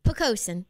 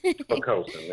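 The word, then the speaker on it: Poquoson: We cover the Norfolk, Virginia Beach, Chesapeake, Portsmouth, Hampton, Newport News, Williamsburg, Yorktown, James City, and I think it's pronounced Pocosin. – Pocosin, yes. – Pocosin, there we go.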